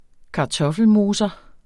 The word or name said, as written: kartoffelmoser